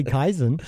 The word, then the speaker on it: Kaizen: I can say Kaizen.